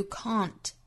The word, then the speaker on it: can't: 最后半句 and then you can't and 尾部的的略掉 and 这个词做弱读 and then you can't 最后的 can't 这个词在英音之中、注意元音是一个 r 的发音 you can't and then you can't 好我们来用非常慢的速度用英音来读一下这句话 One of the odd things about life is sometimes you can do something Until you want to show someone, and then you can't.